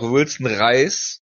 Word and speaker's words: Wilson Reis: Gut, äh, dann natürlich noch Wilson Reis. In Klammern wie das Lebensmittel. Erstens, weil es zur Show passt und zweitens, weil ich recht lange gebraucht habe, um die richtige Aussprache herauszufinden.